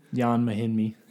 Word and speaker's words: Ian Mahinmi: And then the Wizards would be the third team involved, so you get Ian Mahinmi. – Jan Mahinmi.